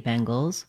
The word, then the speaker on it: Bengals: To pronounce Bengals, as in the Cincinnati Bengals, it's the same as Bengal plus the Z sound.